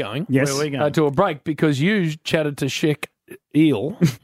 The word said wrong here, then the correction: Shaquille O'Neal: Oh, Shaquille, did I? – Got to say it correctly. Yeah. Shaquille O'Neal.